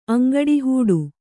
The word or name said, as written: ಅಂಗಡಿಹೂಡು